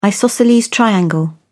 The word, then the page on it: isosceles triangle: British English